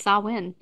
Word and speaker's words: Samhain: I've only heard Samhain. – Samhain. Oh, yeah. I hate to say it, but like Google pronounce. – And when you do the lady, the robot lady? – Yep. It's Samhain.